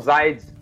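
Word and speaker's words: Zeid: É, e aí é justamente nisso que eu ia chegar, porque a, o atual é, comissário, que é o Zaid Raad, né? É, falei certo? – É, eles falam Zaid,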